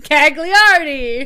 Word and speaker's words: Gagliardi: Gonna butcher your last name, Gagliardi. – No, you said it right.